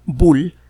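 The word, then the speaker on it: Boole: Bull en el siglo XIX.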